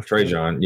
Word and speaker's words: Trajan: Trajan, yeah. That silent J always gets me.